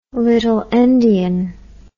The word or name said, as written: little endian